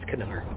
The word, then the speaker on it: Kanar: I hope it's pronounced Kanar. I think it is. – I think it's Kanar.